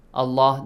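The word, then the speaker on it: الله: Allah, the word Allah, the long, will be pronounced heavily.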